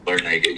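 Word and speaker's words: Larrañaga: Larinaga, yeah. Because, like, they beat. – You better watch how you say that, bro. – They beat. – That's exactly how you say it correctly.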